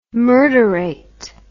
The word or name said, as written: murderate